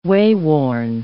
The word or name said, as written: wayworn